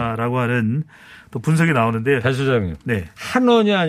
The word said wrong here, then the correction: 환원: 아니고 환원. 아, 제가 한원이라 그러지 않았나요? 네. 환원이라고 좀 발음을 좀 해주세요.